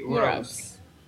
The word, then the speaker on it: Burroughs: Es Naked Lunch, o como se traduce acá, El almuerzo desnudo, de William Burroughs. No sé cómo se dice. – Sí, Burroughs.